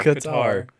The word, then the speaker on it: Qatar: Quater. United States, otherwise known as Circuit of the Americas, and Interlagos down in Brazil. – Quater. – How do you say it? – Qatar. – Qatar.